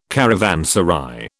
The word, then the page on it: caravanserai: British English